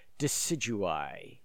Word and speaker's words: Decidueye: Decidueye.